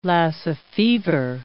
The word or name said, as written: Lassa fever